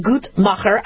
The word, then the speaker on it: Guttmacher: And so the Guttmacher Institute, that's how you pronounce it, Guttmacher,